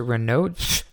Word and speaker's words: Raynaud: And so usually if if somebody has Renaud's syndrome or Raynaud's syndrome, they're saying you pronounce it Raynaud Raynaud's syndrome.